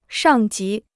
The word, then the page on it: 上级: shàng jí